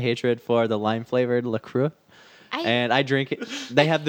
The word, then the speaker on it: La Croix: How do you pronounce it? – La Croix. – La Croix.